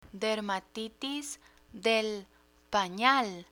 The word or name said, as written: dermatitis de panal